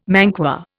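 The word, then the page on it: manqué: Indian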